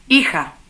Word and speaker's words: hija: Como sabes, en español la H no se pronuncia nunca. Escucha y repite las palabras siguientes. Hija.